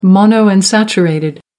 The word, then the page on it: monounsaturated: American English